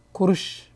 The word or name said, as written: chalk